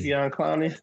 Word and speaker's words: Jadeveon Clowney: Jadeveon Clowney.